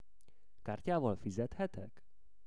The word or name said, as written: Kartyaval fizethetek